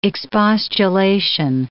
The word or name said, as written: expostulation